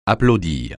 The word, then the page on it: applaudir: francais